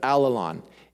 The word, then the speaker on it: allelon: The word that describes this ethic in the New Testament is the Greek word, I don't know how to pronounce that, allelon. It sounds like all alone, but actually it means the opposite of all alone.